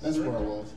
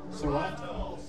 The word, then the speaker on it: Sorrento: What do y'all call it? – Sorrento. – Oh, that's where I was. – Sorrento. – Sorrento. No, not Toronto. – Sorrento.